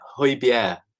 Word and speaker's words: Højbjerg: But yeah, Højbjerg, Højbjerg is how you pronounce it, apparently.